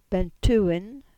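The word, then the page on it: Bentewyn: Cornish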